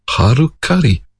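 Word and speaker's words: Halcali: Well, it actually ends up getting pronounced as Harukari, which sounds awfully like Harikari, a particular method of suicide in order to regain one's honour.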